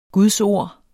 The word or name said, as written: gudsord